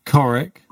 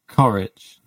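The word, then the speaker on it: Ćorić: So, pronounce Coric like the itch on the end of Coric, not Coric.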